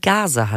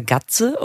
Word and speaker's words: Gaze: Da steht ja immer G-A-Z-E. Das wird gase handschuh ausgesprochen, oder? Gase. Nicht Gaze oder Gatze oder Gazy oder Gaze, Gase ne?